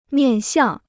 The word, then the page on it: 面向: miàn xiàng